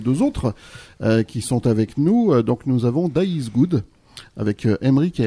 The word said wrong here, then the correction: This is Good: C'est This is Good. Ah ouais, bon, voilà, il y a toujours des hésitations sur la prononciation. Pardon, donc c'est This is Good. En trois mots.